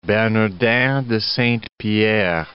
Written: Bernardin de Saint-Pierre

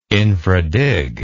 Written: infradig

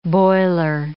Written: boiler

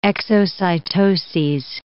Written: exocytoses

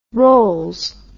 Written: rolls

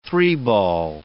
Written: three-ball

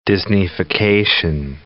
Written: Disneyfication

